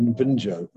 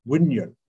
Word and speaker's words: Wunjo: Would it be Wunjo? No, Wunyo. Wunyo. That's how it's pronounced.